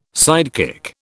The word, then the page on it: sidekick: British English